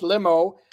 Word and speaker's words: LLMO: Looks like money offense, LLMO, pronounced limo.